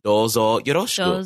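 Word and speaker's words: Dozo yoroshiku (どうぞよろしく): Watashi wa des, and then the last part is just dozo yoroshiku. – Dozo. Like, nice, how do you say it? Dozo oroshiku? – Dozo yoroshiku. So yoroshiku.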